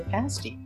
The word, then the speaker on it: pasty: You're listening to the local fairy tale of the pasty. As somebody said, it rhymes with nasty, and I guess that's true.